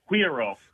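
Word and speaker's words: güiro: Well, I get it wrong all the time, but my principal is from Latin America. He says cuiro. – Cuiro. – Cuiro. – Yeah. – Okay, cuiro.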